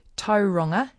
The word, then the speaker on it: Tauranga: In a word also like tauranga, we have a tendency to, with words like whangarei, to onga it. So it ends up being whangarei with a hard G. We hear a lot of people doing that. Tauranga. – Tauranga. – Tauranga. Tauranga. What is with the A-N-G-A sound? We need to keep that as an A sound.